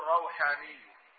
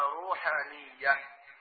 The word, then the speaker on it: روحانية: طبعا بعض الناس ينطق خطأ يقول روحانية، لا، إنما روحانية نسبة إلى ماذا؟ الروح.